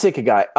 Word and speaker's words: ikigai: How would you, am I pronouncing it right? I looked at your eyes and it seemed like. – Yeah, no, it's Ikigai. – What is it? – It's kind of, well, the model I like is really layered. So for the simplest version of it, it's important to one, spell it correctly. It's I-K-I-G-A-I, Ikigai. It's Japanese.